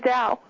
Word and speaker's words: Dao: You got it right, Alea Dow. – Dow. Well, that's an interesting last name. – It is. – It's spelled D-A-O.